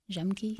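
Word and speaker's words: Jemc: I've recently discovered her, Jacques. It's J-E-M-C, Jemke.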